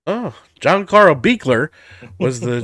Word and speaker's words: Buechler: His last name is hard to pronounce. It's Buechler, if you can get it out Buechler.